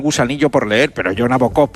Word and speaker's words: Nabokov: Con una amiga rusa el otro día y me dijo cómo se pronuncia correctamente, Nabokov. – Nabokov.